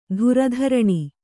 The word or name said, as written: ಥರಥರನೆ